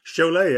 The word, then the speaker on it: Cholet: Cholet, exactly, and excellent pronunciation, I think.